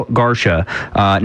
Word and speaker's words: Garcea: Yes, G-A-R-C-E-A is how you spell Garsha. Not any other way. I'm not going to confuse people, but G-A-R-C-E-A.